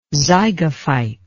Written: zygophyte

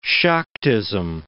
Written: Shaktism

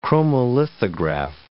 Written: chromolithograph

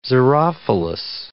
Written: xerophilous

